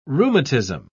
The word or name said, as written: rheumatism